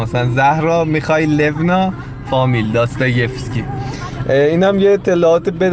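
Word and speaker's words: داستا یفسکی (Достоевский): بله بانوی میزبان اسم کتاب نویسنده های داستا یفسکی هر تلفظ دیگه غلط داستا افسکی و داستا یفسکی اینا داستا یفسکی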